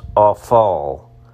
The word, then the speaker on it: afal: The Hebrew term there is pronounced afal.